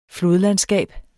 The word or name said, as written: flodlandskab